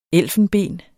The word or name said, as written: elfenben